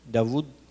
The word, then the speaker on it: Dawood: See, the word al-Wadud is like David, Dawood. That's how you say his name in Hebrew, in Arabic, Dawood, which is from the word Wadud.